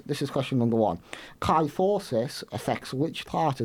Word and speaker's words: kyphosis: Kyphosis, like osmosis, but with a K.